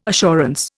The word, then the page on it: assurance: Indian